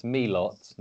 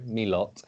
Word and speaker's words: Milot: Finally, I have looked at the pronunciation and basically, as far as I can tell, it's Milot, not Milo, Milot, Rashica. So not Rashica, Rashica.